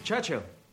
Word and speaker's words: Churchill: Churchill. That's how you say it. All together like that. Churchill. Oh, well, that explains a lot. You see, I'd heard church and hill, but couldn't find either.